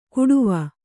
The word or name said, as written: ಕುಡುವ